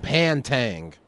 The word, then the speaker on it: Pantang: T-Y-G-E-R-S. – No, sorry. The Tigers of... What's it called? – Pantang. – P P-A-N-T-A-N-G. T-A-N-G.